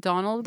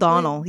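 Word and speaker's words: Domhnall: That's how he says it. Like, I looked it up one day because I saw it on some Tumblr post where he was like, please just say my name correctly if you're going to be interviewing me. – We all call it Donald. – Donal, yeah. But it's Donal. Because he's Irish.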